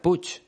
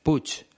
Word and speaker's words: Puig: Por cierto, su segundo apellido se escribe P-U-I-G, pero se pronuncia Puch, como si llevara una C y una H al final. Puch. Es un apellido de origen catalán, de Cataluña. Por eso se pronuncia de una manera diferente al español.